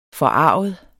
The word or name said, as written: forarget